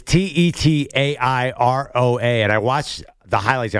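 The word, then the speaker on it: Tetairoa: So the I is silent. – Yes, Teetaroa.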